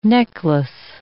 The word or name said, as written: necklace